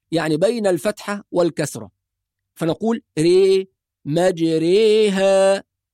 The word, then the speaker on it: مجريها: نعم، لو نظرنا إلى المصحف هنجد دائرة مطموسة تحت حرف الراء، وهذه الدائرة معناها أن حرف الراء ينطق بالإمالة، فلا نقول مجراها ولا نقول مجريها، لا تنطق فتحة ولا تنطق كسرة، وإنما تنطق بين الفتحة والكسرة، هذا هو معنى الإمالة.